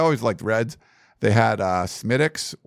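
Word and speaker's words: Smithwick's: It's spelled Smithwix, but it's Smittix. You just say that.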